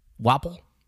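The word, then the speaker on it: Wapol: Wapole? Am I saying it wrong? What is it? – Wapole. – Wapole? – Wapole. – Like tadpole. – Wapole, yeah. – Like waffle. – Exactly.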